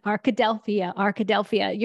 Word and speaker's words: Arkadelphia: I've never been to this town, but Arkadelphia. – Yes. – Arkadelphia.